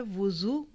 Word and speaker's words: वुज़ू: याद रखिए दो लफ्ज़ हैं, एक है वुज़ू वाव की पेश, और एक है वज़ू वाव की ज़बर, और तीसरा लफ्ज़ है विज़ू वाव की ज़ेर के साथ। तीनों के प्रोनाउंसिएशन फ़र्क है।